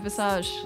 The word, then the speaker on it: Visage: So we're starting Visage. Am I saying it correctly? – Yeah, Visage.